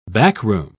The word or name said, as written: backroom